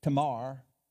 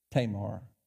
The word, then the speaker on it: Tamar: I always want to pronounce it Spanish, Tamar, but it's Tamar, I think.